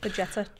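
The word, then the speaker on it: Bajčetić: Most excited, I'd start Bajetic, or however you pronounce it. – Bajetic. – There we go.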